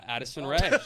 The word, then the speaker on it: Addison Rae: Her name rhymes with that is gay. Yeah, Addison Rae.